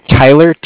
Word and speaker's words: Tyler: My name is Tyler, T-Y-L-E-R. – Uh-huh. – And I'm calling with Cardiff. It's spelled C-A-R-D-I-F-F, as in Frank Frank.